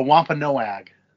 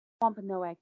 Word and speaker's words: Wampanoag: Oh, Wampanoag. – Wampanoag. The Wampanoag Chief. – Oh, no shit. Okay. – Yes. So, like. – So, refer to one of our earlier episodes where we talk about the Wampanoag Chief.